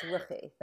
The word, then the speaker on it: Ruchi: It's Ruchi.